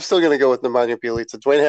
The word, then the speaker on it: Nemanja Bjelica: I'm going to go with Nemanja Bjelica. He was he used to be a Wolves player, which is why I know how to pronounce his name.